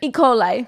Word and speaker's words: E. coli: Oh wait, no, E. cola. – E. cola. – I can't even fucking say it right though when I know what it is. – E. coli.